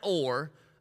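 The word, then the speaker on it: Hor: From Mount Or, I'm pretty sure the H is silent. If not, it should be.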